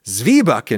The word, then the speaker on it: Zwieback: I heard Zweibach, and so I say Zweibach, and I said Zweibach in the video, and some dude just sent me a well actually email informing me, oh, it's pronounced Zwieback in Germany, and I replied, dude, I'm American, I speak with an American accent, and I don't think there's anything wrong with that.